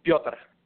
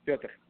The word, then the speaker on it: Pyotar: Piotr. – Joseph? – Piotr. Piotr. – I'm sorry, could you repeat that one more time, please? – Yeah, Piotr. – Would you spell that, please? – Yeah, P-Y-O-T-A-R.